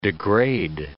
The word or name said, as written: degrade